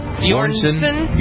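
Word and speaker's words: Björnsson: Is that how you pronounce his name? – Bjornsson. – Bjornsson. Right. – You like ABBA? You should know how to say Bjorn. – Bjorn, yeah, but Bjornsson, two S's.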